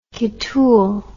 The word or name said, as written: kitool